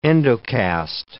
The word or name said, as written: endocast